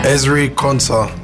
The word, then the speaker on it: Ezri Konsa: Ezri Konsa.